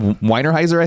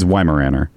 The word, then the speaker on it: Weimaraner: I said Weinerheiser, I think I said. – Weinerheiser? – Yeah, like a beer. – Instead of what? – What is Weinheiser? – It's Weimaraner.